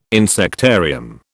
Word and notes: insectarium: British English

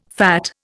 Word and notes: fat: Indian

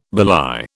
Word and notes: belie: British English

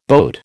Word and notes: boat: American English

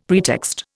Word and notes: pretext: Indian

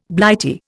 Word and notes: Blighty: Indian

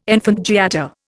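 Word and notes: enfant gâté: Indian

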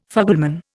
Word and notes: fugleman: Indian

